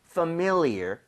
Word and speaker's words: familiar: 그래서 familiar보다는 familiar. 그래서 familiar. MI 쪽에 강세를 주고 한번 발음해 볼게요.